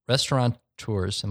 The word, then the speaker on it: restaurateur: Step one, figure out what chefs and restaurateurs, am I saying that right? Restaurateurs? – Restaurateur? Yeah, restaurateur.